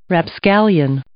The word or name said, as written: rapscallion